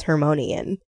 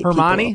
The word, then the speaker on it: Hermione: That's like how me in first grade thought that Hermione's name was Hermonian in Harry Potter. I would, like, fight Hermione? – People over it. – I can never say her name. What is it? – Hermione. – Hermione?